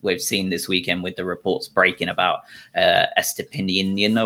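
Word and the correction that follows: Estupiñán: or whatever his name is. I'm going to butcher it. Purvis. We'll just call him Purvy from now on. – Estupinian. – Estupinian. Yeah.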